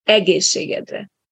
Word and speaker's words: egészségedre: where you learned that the stress always goes on the first syllable. That's especially important with this one. So it's egészségedre.